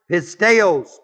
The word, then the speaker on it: pisteos: The word him is the word ton, T-O-N. The one, which literally means everyone, which believeth. The word believeth is the word pisteos, P-I-S-T-E, long O-S.